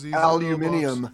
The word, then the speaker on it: aluminium: Aluminium.